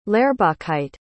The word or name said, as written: lehrbachite